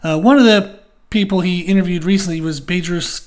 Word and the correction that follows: Bedros: Badrus is Bedros. And then his last name is Keuilian.